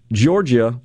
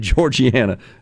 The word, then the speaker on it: Giorgia: So, was just talking about what I think is truly a transformational moment, this election of Georgia. I mispronounced her name. I apologize. I said Georgiana. Georgia, G-I-O-R-G-I-A.